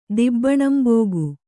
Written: ದಿಬ್ಬಣಂಬೋಗು

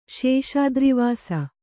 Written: ಶೇಷಾದ್ರಿವಾಸ

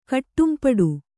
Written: ಕಟ್ಟಂಪಾಡು

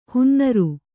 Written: ಹೊನ್ನಾರು

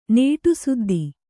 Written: ನೇಟುಸುದ್ದಿ

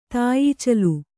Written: ತಾಯೀಚಲು

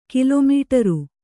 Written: ಕಿಲೊಮೀಟರು

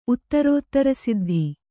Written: ಉತ್ತರೋತ್ತರಸಿದ್ಧಿ

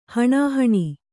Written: ಹಾಣಾಹಣಿ

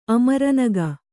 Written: ಅಮರನಗ

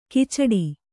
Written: ಕಚ್ಚಡ

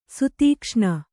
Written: ಸುತೀಕ್ಷ್ಣ

